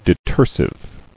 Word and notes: Detersive: de·ter·sive

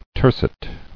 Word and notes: Tercet: ter·cet

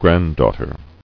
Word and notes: Granddaughter: grand·daugh·ter

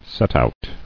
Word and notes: Setout: set·out

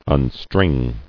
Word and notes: Unstring: un·string

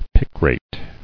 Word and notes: Picrate: pic·rate

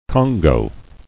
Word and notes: Congou: con·gou

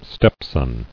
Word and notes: Stepson: step·son

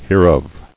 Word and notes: Hereof: here·of

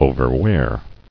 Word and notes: Overwear: o·ver·wear; O`ver*wear"

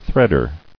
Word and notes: Threader: thread·er

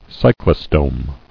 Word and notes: Cyclostome: cy·clo·stome

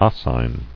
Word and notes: Oscine: os·cine

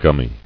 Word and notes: Gumminess: gum·my

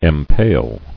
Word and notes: Empale: em·pale

